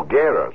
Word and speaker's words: Guerra: No, Guerra. Excuse me, he says you don't pronounce the U.